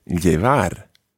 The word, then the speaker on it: llevar: To begin with, I'm going to give you a new verb, and this verb is llevar. Llevar. Now, you should already be thinking, I know how to spell that, because the first sound, llevar, the first sound is double L, double L-E-V-A-R, llevar.